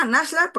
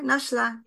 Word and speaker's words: Najla: Nashla, ¿pronuncio bien tu nombre? Nashla.